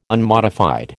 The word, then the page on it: unmodified: American English